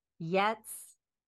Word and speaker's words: jetzt: Jetzt.